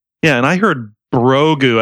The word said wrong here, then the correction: Grogu: as in Brogu. – Yeah, I looked it up too just to see if anyone else was, because I didn't know how to spell it, and it is Grogu, and everybody's spelling it G-R-O-G-U is what it is.